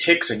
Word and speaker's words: Rickson: I thought there was a guy named Rickson. Then I realized, oh, his name is Hickson. You just mispronounced you HDR. I'm like, okay, Hickson, but he still partners with one of the Gracies.